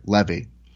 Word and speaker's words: Levy: Is it Levy or Leb? – Hold on. I have a note here. I had to do it phonetically. I have Levy.